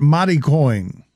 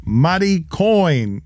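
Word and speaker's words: Mari Coin: M-A-R-I coin. Mati coin. I'm not making it maritime. I'm pronouncing it Hispanically. – Yeah, well, be careful with that, especially. They're going for something. – Mati coin?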